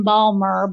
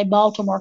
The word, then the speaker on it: Baltimore: The weekend before Samhain, I'm going to be in Baltimore. I know that's not how they say it in Balmer, but I say Baltimore because I'm not from there.